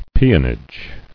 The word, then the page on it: Peonage: pe·on·age